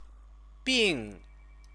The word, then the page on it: bing: chinese